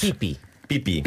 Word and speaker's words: pipi: É pipi. – Pipi. – A sílaba tónica é o primeiro i.